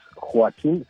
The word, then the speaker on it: Joaquín: Por Joaquín Phoenix, el nombre de Joaquín, es inclusive él pide que se pronuncie como se pronuncia en español, ¿no? No es Joaquín ni Joaquín, no, es Joaquín Phoenix, porque sus padres así se lo pusieron cuando vivieron acá en México,